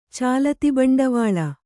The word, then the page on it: ಚಾಲತಿಬಂಡವಾಳ: cālati baṇḍavāḷa